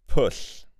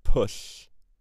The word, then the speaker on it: Pwll: Pwll. Pwll.